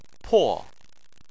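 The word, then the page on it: po: tone 4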